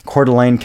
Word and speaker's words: Coeur d'Alene: Coeur d'Alene kennt man, das ist ein Lake Coeur d'Alene, wird aber französisch geschrieben. aber Redneck ausgesprochen.